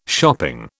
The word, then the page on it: shopping: British English